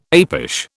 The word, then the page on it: apish: British English